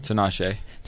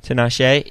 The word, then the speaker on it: Tinashe: Tinashe. – Tinashe.